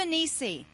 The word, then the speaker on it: Nissi: Is it Nissi or Nisi or... Jehovah Nissi. There we go.